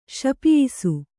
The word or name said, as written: ಶಪಿಯಿಸು